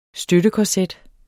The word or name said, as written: støttekorset